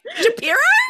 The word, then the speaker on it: Shapiro: sharpio has a blue tick Sharpio. Sorry. How do we pronounce his name? Shapiro? Ben Shapiro.